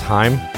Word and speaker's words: Haim: Yeah, I guess Haim is really spelt, or it's pronounced Haim. – Haim? – Okay. Yeah, I don't know. It's supposed to rhyme with time. I don't think Haim rhymes with time.